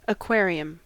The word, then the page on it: aquarium: American English